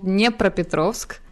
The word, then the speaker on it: Dnepropetrovsk: It's called Dnepropetrovsk.